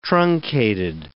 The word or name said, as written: truncated